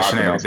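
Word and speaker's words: Sinéad: How do you pronounce it again? I'm sorry. Shin- I thought it was Sinead O'Connor. – That's yeah, Sinead.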